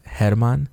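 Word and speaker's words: Germán: Okay, so my name is German. It's pronounced Hermann.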